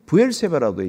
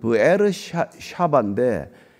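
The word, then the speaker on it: 부에르샤바 (באר שבע): 그런데 이 샘에 관한 이름이 부엘세바라고 되어 있잖아요. 이 부엘세바가 맹세의 우물이란 뜻이에요. 원래는. 그런데 이게 원래 발음은 부에르샤바인데 이 쉐바라는 게 일곱이라는 뜻이란 말이에요.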